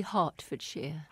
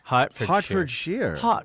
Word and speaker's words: Hertfordshire: Charles Chauncy was born at Yardlebury, Hertfordshire, England. – Mum, Hertfordshire? – How do you say it? – It's actually Hertfordshire. – Hertfordshire.